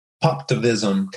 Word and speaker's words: Poptivism: Poptivism, kind of Poptivism. – Like activism, yep. With pop at the beginning.